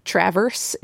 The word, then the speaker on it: Traverse: But yeah, because the same thing happened in Station 11, which takes place around Traverse City, which is, it's spelled like the verb traverse, and that's how people pronounce it when they read it. But it's traverse is how we say it.